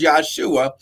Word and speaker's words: Yeshua: Uh, glory, it would not have been pronounced Jesus because there's no J in the Hebrew language. So his name was Yeshua in Hebrew.